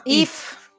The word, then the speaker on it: Eva: Sí, no, pero es curioso que lo pronuncian If y luego escriben Eva. Que te quedas un poco mosqueado. – Como en inglés es if.